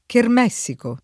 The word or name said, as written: chermessico